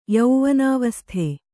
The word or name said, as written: ಯೌವನಾವಸ್ಥೆ